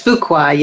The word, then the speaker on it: Fuqua: Fuqua, am I saying that right? – It's Fuqua, yes.